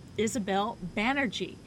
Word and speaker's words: Isabel Banerjee: Isabel Banerjee. Now, I pronounced that right. I should have asked you before we started, right? – You were absolutely correct. Okay. Banerjee like energy.